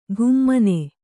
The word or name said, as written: ಘುಮ್ಮನೆ